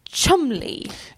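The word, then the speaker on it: Cholmondeley: Men får jag lov att testa Och sen så Cholomondeli uttalas Chumley.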